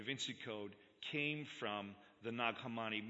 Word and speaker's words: Nag Hammadi: is the study of the Nag Hammadi. And I don't know if I said that right, Ramsey. It's Nag Hammadi. I got to get that, you know, that sound in there.